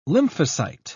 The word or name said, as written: lymphocyte